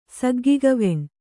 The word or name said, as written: ಸಗ್ಗಿಗವೆಣ್